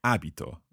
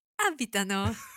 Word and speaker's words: abito: Abito, abiti, abita. Abitiamo, abitate, abitano. – Now this is interesting because the stress actually changes for all of this verb.